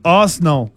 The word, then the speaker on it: Arsenal: ¿Ustedes sabían que en Inglaterra le dicen en dos sílabas? Arsenal.